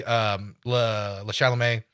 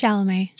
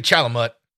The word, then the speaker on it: Chalamet: As Timothy Chalamet. I might have just completely butchered his name, but I don't care. – It's Chalamet. – Chalamet? – Yeah. – Timothy Chalamet.